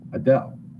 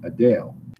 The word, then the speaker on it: Adele: For years, people were pronouncing Adele's name Adele, but apparently in October, she said people have been mispronouncing it, and it's supposed to be pronounced in her own London accent, which is more like Adele.